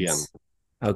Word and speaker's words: Jens: J-E-N-S. – J-E-N-S. Okay. – Everyone calls him Jens, but the way he pronounced it in Danish Jens. Is Yen. – Okay. Yeah. Yen. Like the Chinese money, the yen? – Yeah, I guess that's how.